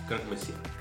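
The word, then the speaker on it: Croque Monsieur: Los en Monsieur, ¿cómo se pronuncia eso? – Croc Monsieur.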